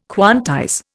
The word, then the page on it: quantize: Indian